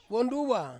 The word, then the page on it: wonduwa: Ansus